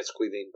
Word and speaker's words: Caoimhín: Is it Keyvine? What's his name? You know, you could have just done that. – Yeah, no, It's Queenine, by the way.